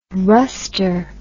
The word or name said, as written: rustre